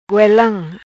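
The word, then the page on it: Goelam: patua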